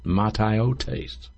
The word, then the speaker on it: mataiotes: The technical word, as I'll pronounce it in the Greek for you, is mataiotes. Mataiotes in Ephesians 4:17 is translated vanity of the mind.